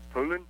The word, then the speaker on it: Poulan: It's P-O-U-L-A-N. Is that Poulan? – I don't know what it is, but I hear everybody around here call it Poulan.